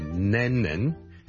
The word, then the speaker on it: Lennon: 第一個字呢就是人名來的， John Lennon， John Lennon， John Lennon，一個英國的很出名的歌手作曲家， Lennon， L-E-N-N-O-N，第一個是 L 音，所以不要將它讀成 N 音，不是 Lennon，是 Lennon， Len， Le len 后面嗰个先系 N 音，就 Lennon 仲要两个 N 音添。